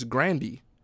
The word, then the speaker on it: Grande: Ariana Grande. Oh, matter of fact, it's Grandy. – Okay. – Yeah, like per her pronunciation in an interview that I heard, it's Grandy.